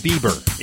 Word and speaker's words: Bieber: Dude, did you say fiber? – Yeah, fiber. What's a fiber? Yeah, Justin Bieber, that pop star kid? It's pronounced Bieber.